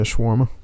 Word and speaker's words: shawarma: I hope that's how you pronounce it. – Yeah, shawarma.